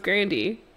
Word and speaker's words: Grande: whose last name is actually pronounced Grandy, but she just stopped correcting people, is an Italian white woman.